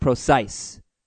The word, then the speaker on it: Procise: Now, Trust the Procise is a little bit faulty because if you watch the game, it is apparently pronounced Procise. So, like, precisely.